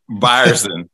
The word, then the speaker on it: Byerson: Byerson. It's all right. Everybody pronounce it wrong.